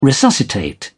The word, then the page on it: resuscitate: British English